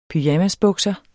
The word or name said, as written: pyjamasbukser